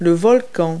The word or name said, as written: volcano